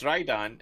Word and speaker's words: Rhydon: You said it right. Rido. It was Rhydon. It's Rhydon.